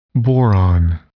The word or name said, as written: boron